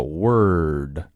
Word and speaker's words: word: R 出现在元音后面往往代表着卷舌，这是美式英语非常有特色的发音，特别适合吃酸菜啊这种感觉。 Word, 我们先发 word, 然后不够 word, 舌头卷起来 word。